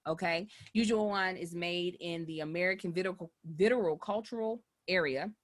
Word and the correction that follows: viticultural: No, it's viticultural. I didn't add so many other syllables.